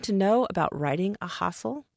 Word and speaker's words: ghazal: When I'm working with my students on this forum, we practice that sound at the beginning, hustle, because often they'll say ghazal or ghazal.